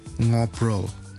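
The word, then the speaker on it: ngobrol: 跟住就係 ngobro ng 嗯。就是用後鼻音發音，ngo pro 傾偈，同埋 ny ny 就好似我哋讀 ye 嘅嗰個前音咁樣，ny 咁樣，ny 唱歌。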